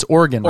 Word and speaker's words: Oregon: And did I say Oregon right? It's Oregon, Oregon.